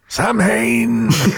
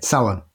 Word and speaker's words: Samhain: But it's not pronounced how Donald Pleasance pronounces it in Halloween. That's all I'll say. – Samhain! – Yeah, Samhain.